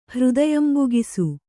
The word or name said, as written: ಹೃದಯಂಬುಗಿಸು